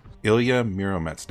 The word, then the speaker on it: Ilya Muromets: And how do you pronounce that, by the way? Ilya? – Ilya. – Ilya. And the second part? – Muromets. – Ilya Muromets. Now see,